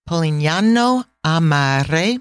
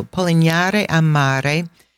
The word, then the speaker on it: Polignano a Mare: So, Polignano a Mare, it's a hard word to say, just like Puglia. The G is uh, not pronounced. So, Polignare Amare, if you Google that, Google image that village, it is so dramatic.